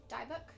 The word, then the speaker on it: Dybbuk: Dybbuk? – The Dybbuk box.